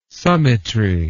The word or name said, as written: summitry